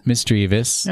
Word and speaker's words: Misdreavus: Misdreavus.